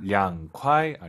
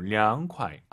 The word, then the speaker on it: 凉快: 凉快 아니고 凉快. 아하. 이 발음 기억하세요.